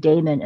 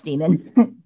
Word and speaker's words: daemon: You know, I actually purposefully try to use the word daemon as often as possible, even though I know that's not how it's pronounced. I know it's still pronounced demon, but I do that on purpose because I'm trying to denote the difference.